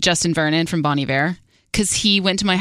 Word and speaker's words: Iver: How do you spell that, Iver? – I-V-E-R. – Oh, like Bon Iver. Iver.